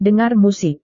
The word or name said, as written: dengar musik